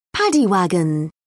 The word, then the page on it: paddy wagon: British English